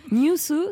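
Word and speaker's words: Nyüsu: On prononce comment ? – Newsu, c'est genre la japonification, si je peux dire, de news.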